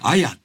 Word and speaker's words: 文: うん、それを別の読み方で、あやって読む。